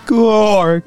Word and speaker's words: Georg: Actually, I believe in this scenario, it would be pronounced Gorg.